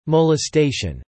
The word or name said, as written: molestation